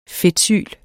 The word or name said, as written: fedtsyl